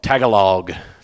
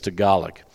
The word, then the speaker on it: Tagalog: I called it Tagalog first time I was here, like a South Georgia person would, but it's Tagalog.